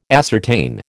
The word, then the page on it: ascertain: American English